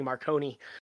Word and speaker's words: Marcone: And we meet another recurring character, Johnny Marcone, or as he's called for the first couple novels, Johnny Marconi. By Mr. Marsters. And actually, that's probably better Italian pronunciation.